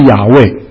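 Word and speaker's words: Yahweh: 今天的学者呢，认为这个名字最可能的发音的是亚卫最可能的发音，意思不是说我是，意思是他是，是因为我们称神是他是，他是自由拥有的，所以亚卫的意思是他，可能是他是的意思。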